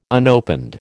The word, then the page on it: unopened: American English